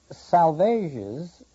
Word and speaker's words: Salvages: The three savages, a small group of rocks with a beacon, and then we find out that salvages rhymes with assuages.